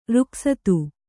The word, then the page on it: ರೊಕ್ಕಸ್ತ: rokkasta